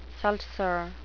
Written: seltzer

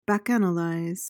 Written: bacchanalize

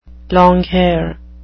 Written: longhair

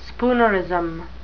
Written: spoonerism

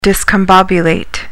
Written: discombobulate

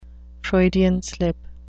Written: Freudian slip

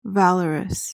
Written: valorous

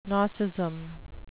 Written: nosism

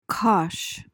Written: cosh

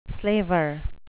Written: slaver

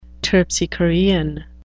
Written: terpsichorean

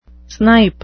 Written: snipe

